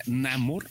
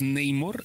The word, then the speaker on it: Namor: Yo siempre le decía Namor, en inglés es Neymor y acá le pusieron Namor.